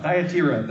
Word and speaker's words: Thyatira: Thyatira, that's how you pronounce that town.